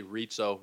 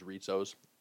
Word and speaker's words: Rizzo: Anthony Rizzo really is how the Italian people would pronounce it. I have Rizzo on my own family. There's Rizzos. But Rizzo is the Americanized version that we go by, that Anthony goes by.